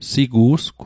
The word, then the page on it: sigu'sgw: Mi'gmaq